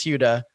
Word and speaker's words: Huda: Well, you do it pretty well, but you make kind of a D sound, and it's Huda.